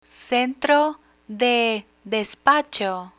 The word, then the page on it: centro de despacho: spanish